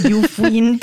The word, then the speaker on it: Yufuin: Can you tell us what's it like exploring the, how do you say it, Yufuinna? Or how do you say it, the Japanese floral village? – Honestly, I have no idea. Okay, I would say it's Yufuin too. – Okay, so it's spelled Y-U-F-U-I-N, Yufuin Floral Village, which some people say is just as charming as England's Cotswolds Village.